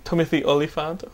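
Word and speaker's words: Timothy Olyphant: Have you seen these shots of Timothy Oliphant? Am I pronouncing that right? Timothy Oliphant. Thank you, Thespian.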